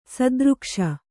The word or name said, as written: ಸದೃಕ್ಷ